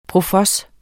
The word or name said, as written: profos